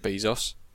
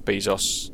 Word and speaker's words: Bezos: I just saw the headline of Jeff Bezos agrees $35 billion divorce settlement with wife. – Is that how it's said, Bezos? I always assumed it was like Bezos.